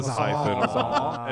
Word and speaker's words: Sawzall: Sawzall. S-A-W-Z. – The paisas all on our cruise, they call them sosos. Sosos. And la soso. – Sawzall. No one pronounces the L's. You don't have to.